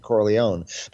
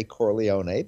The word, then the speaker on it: Corleone: But I think when they all decided to become American, they started pronouncing it Corleone. But you will hear people every once in a while in the movie call it, you know, say Corleone, because they don't understand the family history.